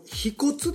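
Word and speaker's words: 腓骨: あの飛骨ですね、飛骨、あ、これ飛骨って読むんですね、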